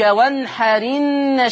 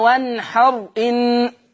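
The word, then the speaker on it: وانحر إن: فيصير النطق فصلي لربك وانحرن شانئك. وانحرن مش بقى وانحر ان، لا، شيل الهمزة، انقل الحركة بتاعتها لحرف الساكن اللي قبل منها وانحرن شانئك. نفس القضية، هي المفروض احنا بنقول في حفص ايه؟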